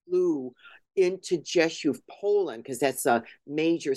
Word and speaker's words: Rzeszów: which is Rzeszów. I had a cousin who used to live there, and it's spelled R-Z-E-S. Z-O-W, but it's pronounced Rzeszów. You know, don't ask me why. That's the Polish pronunciation.